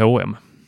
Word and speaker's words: H&M: På svenska heter det ju bara H&M. – Just det. – Medan de uttalar ju och-et som är där mellan bokstäverna. Fattar aldrig vad det var.